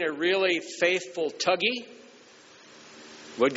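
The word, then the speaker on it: Thuggee: Well, that's the way the Indians pronounce it. It's spelled thug. That's where we get the word thug, is from tug, T-H-U-G. Tuggy is, I think, got an extra G and then an I. Tuggy is the way they pronounce it.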